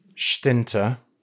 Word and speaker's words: Schtinter: My surname is pronounced Schtinter and Last Movies is the first book that I've written.